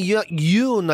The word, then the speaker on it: Youn: this doctor and surgeon and YouTuber, Dr. Anthony Youn, I think you pronounce it, Y-O-U-N.